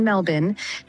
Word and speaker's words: Melbourne: I'm impressed at an American pronouncing Melbourne correctly. She's saying Melbourne, 12 away from 7.